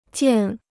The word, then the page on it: 件: jiàn